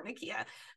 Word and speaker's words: Nakia: Nakia together. And to not have that relationship after the first Black Panther was kind of like, for my romantic heart, was like, oh, poor Nakia.